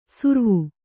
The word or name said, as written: ಸುರ್ವು